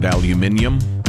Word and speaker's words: aluminium: I prefer to pronounce it aluminium, like our British friends.